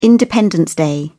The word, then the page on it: independence day: British English